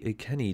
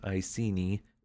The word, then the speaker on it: Iceni: Well, quickest of overviews, she briefly ruled as queen of the Iceni tribe, also sometimes pronounced Iceni, who lived around the area of present-day Norfolk in eastern England.